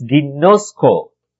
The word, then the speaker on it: ginosko: Now the word know in Greek is ginosko. Okay? That is spelled as G-I-N-O-S-K-O.